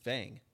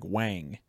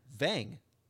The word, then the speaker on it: Vang: His Vietnamese name is Vang. And just like, so his sister's name is Andrea, but you say it like Wang. – Vang. – Yeah, Wang. – No, there's a V, not a W.